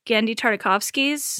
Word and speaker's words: Genndy Tartakovsky: It was a reboot of Genndy Tartakovsky's.